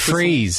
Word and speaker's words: Fries: Like it's Fries. Just... It's but it's spelt in a very Germanic oh, okay.